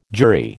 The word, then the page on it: jury: American English